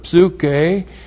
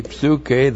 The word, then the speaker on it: psuche: And then we have the accusative singular of psuche. P-S-U-C-H, long E, psuche, that's the word for soul or life.